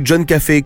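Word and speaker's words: Coffey: quand on tue John Café comme le café, sauf que ça s'écrit pas pareil, alors que c'est le mec le plus gentil du monde.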